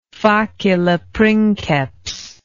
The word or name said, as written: facile princeps